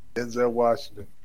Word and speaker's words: Denzel Washington: Did I say that right? Denzel, what's his name? The actor's name? – Denzel Washington. – Is it Denzel Washington? – Yeah,